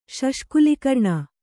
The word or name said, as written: ಶಷ್ಕುಲಿಕರ್ಣ